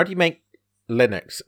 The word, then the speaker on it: Linux: Linux.